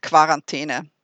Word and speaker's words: Quarantäne: Is it Quarantäne or Quarantäne? – Oh, I'm 100% Quarantäne. That's where I'm coming from.